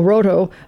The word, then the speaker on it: Rohto: I guess, R-O-H-T-O, Roto, yeah.